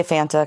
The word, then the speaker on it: Fanta: It depends who you ask. I say Fanta because it rhymes with Santa.